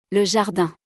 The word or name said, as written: Le jardin